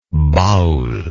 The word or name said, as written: baul